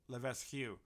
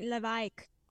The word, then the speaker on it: Levesque: I've kind of Englified it. My family has, but in the French pronunciation, it's like Levesque. That's how you say it in French. Levesque. So it's a little bit strange, but I've just turned it into Levesque, and yeah, definitely gives me a different edge with a different last name.